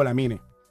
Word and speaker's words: Lamine: Y una cosa, y es que pues mucha gente me dice, ¿qué se pronuncia? ¿Lamin o Lamine? A ver, literalmente, técnicamente, se pronuncia Lamin. Uh-huh. ¿Por qué? Porque la es muda debido a ese tinte francés.